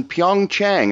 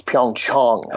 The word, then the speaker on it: Pyeongchang: How are things there in Pyeongchang? Am I saying that correctly? – No, you're not. It's Pyeongchang.